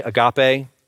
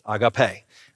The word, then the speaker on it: agape: agape. Now, some people say agape. That's not how you pronounce it. It's agape.